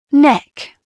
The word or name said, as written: neck